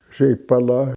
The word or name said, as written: sepolah